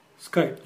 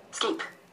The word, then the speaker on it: Skype: سكايب. نشوف هنطقها ازاي. – سكيب. Actual. – نطقها سكيب. طب احنا عايزين نحل المشكلة دي. عايزين نخليه اول ما يشوف كلمة سكايب يقولها سكايب. ما يقولهاش سكيب.